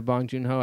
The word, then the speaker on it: Bong Joon-ho: Bong, was it Jo-hoon? – Yeah, Bong Joon-ho, I think. – Joon-ho, okay, I remember what it is.